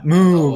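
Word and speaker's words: MØ: Yeah, Moo, oh. But it's spelled with like the O's, like it's M-O, but the O is a Greek symbol, Omega or whatever.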